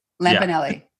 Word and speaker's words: Lapinelli: And Lisa Lapinelli. Am I saying it right? – Lapinelli. – Yeah, I mean, like, that was hard.